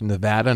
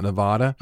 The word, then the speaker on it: Nevada: It's pronounced Nevada, not Nevada.